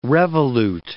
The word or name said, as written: revolute